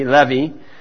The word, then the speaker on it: Levi: And Levi, they would pronounce that probably Levi. The I is like an E, Levi.